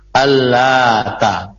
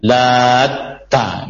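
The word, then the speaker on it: Allata (اللات): Adapun kata Allata, maka jumhur mayoritas para ulama membacanya dengan tidak memberikan tasydid pada huruf ta. Allata, Allata.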